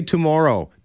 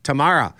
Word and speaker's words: tomorrow: Say the word more, more. Say oh, oh. Now say tomorrow, Tamara.